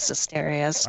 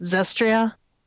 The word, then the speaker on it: Zestiria: I think this game completely made up for the meh that was I can never pronounce it. Zestria? – Zestiria.